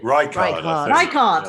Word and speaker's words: Reichardt: How do you pronounce it? Reichardt? Is that right? – Reichardt. – Yeah. That's it.